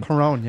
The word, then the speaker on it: Cuarón: guy, Alfonso, and I'll probably say his last name wrong, Cuarón. – Cuarón, yeah.